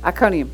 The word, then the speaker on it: Iconium: Is that how you say it? Iconium, yeah.